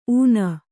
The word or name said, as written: ಊನ